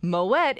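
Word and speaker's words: Moët: According to Google, surprisingly, Moet is pronounced with a hard T and not a silent T, as is typical for most of the French language.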